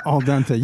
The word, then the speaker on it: al dente: Al dente.